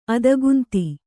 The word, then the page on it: ಅತಿಗಂಧ: ati gandha